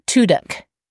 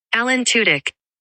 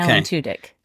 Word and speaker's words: Tudyk: Tuduk.